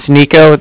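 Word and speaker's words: Niko: Yeah, my name is Nico. That's N-I-K-O. I'm calling with the Bank of Cardiff, and Cardiff is spelled C-A-R-D-I-F-F, as in Frank, so it's like car and then different.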